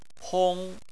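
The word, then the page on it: hong: tone 1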